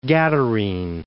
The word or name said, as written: Gadarene